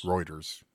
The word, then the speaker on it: Reuters: So apparently he was in Reuters before that and was CEO of Thomson Reuters Markets. – Now, you said Reuters or Reuters? – Reuters.